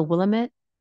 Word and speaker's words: Willamette: She lives in, I think it's the Willamette. Am I saying that right? Valley? – Sounds right.